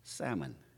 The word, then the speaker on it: salmon: All right, so how do you pronounce, and I'm just going to say it, how do you pronounce salmon? Is it salmon or salmon? – The L is silent. – Yeah. – Salmon. – The L is silent. Yes.